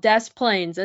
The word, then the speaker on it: Des Plaines: It's called Des Plaines. It's spelled the same way. The Des Moines part. Yeah. – Oh, but they do pronounce the S.